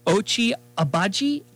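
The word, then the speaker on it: Ochai Agbaji: I'll probably butcher this one, but Ochi Agbaji. – Oh, you got it.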